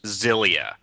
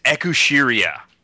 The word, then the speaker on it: Xillia: The it bugs me so much that Xillia in English should be pronounced Zillia, yep. – But the phonetic Japanese on the box is Ekushiria or Excelia, and that bugs me so much.